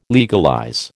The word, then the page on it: legalize: American English